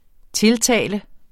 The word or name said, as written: tiltale